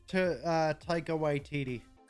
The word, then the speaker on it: Taika Waititi: Takei? – Taika Waititi. – Taika, that's it.